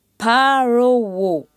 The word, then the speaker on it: Parowo: Parowo, c'est le visage. Prononce bien le P avec une aspiration. Parowo.